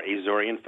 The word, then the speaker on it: Azorean: You are, Azorean food, yes.